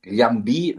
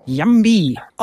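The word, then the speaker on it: Llambí: Also eigentlich gehört auf dem I hinten noch ein Akzent und es wird im Grunde dann wie auch Mallorca oder Lloret de Mar mit einem LJ ausgesprochen. Lambi wäre eigentlich die richtige Formulierung.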